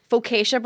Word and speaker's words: focaccia: So sandwiches to go on a pillowy, handcrafted, baked, fresh, daily focaccia bread.